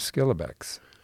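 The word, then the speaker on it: Schillebeeckx: And the theologian we're focusing on in this conversation is Edward Skillebeck. – You got credit for pronouncing that correctly.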